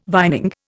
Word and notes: vining: Indian

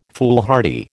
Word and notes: foolhardy: American English

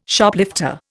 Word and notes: Shoplifter: Indian

